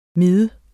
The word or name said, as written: Miderne